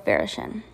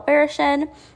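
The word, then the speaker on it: Varaschin: I hope I pronounced that right. I did look it up. I'm going to go with Barishin.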